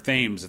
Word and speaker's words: Thames: But the problem I think for the team was that they had that big breakout for Eric Thames at the beginning of the year.